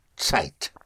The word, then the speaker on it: Zeit: The Z or the Z, yes, is spelled Z or Z E I T, and uh, that is pronounced like a T S. Zeit.